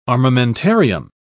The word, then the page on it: armamentarium: British English